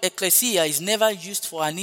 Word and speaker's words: ekklesia: The word church as used in the New Testament is from the Greek word ekklesia. Hallelujah. Ekklesia, which is spelled E-K-K-L-E-S-I-A.